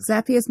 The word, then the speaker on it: Zapier: It's Zapier because Zapier makes you happier.